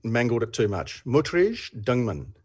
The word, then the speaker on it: Dingemans: And then the family name, D-I-N-G-E-M-A-N-S. Now, I'm assuming because of the French pronunciation, the S is silent. So, Dingman.